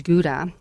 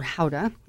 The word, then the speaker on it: Gouda: So right here we have this vintage Gouda, which is, or Gouda, as it's correctly pronounced.